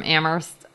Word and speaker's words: Amherst: This is from John from Amherst, Massachusetts. I hope I said that right, because John specified in his email that the H is silent, which is a good reminder.